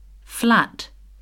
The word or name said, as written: flat